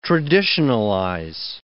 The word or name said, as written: traditionalize